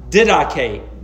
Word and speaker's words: didache: and that is the word didache. And it's spelled, if you are taking notes, the word didache is spelled D-I-D-A-C-H-E.